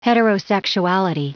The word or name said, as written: heterosexuality